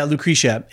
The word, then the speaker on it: Lucretia: Yeah, Lucretia.